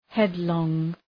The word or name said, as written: headlong